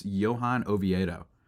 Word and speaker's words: Oviedo: I believe it's Johan Oviedo. Uh, is how you pronounce the name.